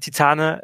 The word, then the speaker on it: Titane: Titane, äh, ähm, also, äh, also ist französischer Film, also spricht man Titan äh, aus.